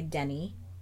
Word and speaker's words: Denis: So, she became a teacher like her parents and then married Dennis Ratte, or as they pronounced it in the French way, Denis. With the accent, but it sounds really weird when you're speaking in, like, a non-French accent, and then right.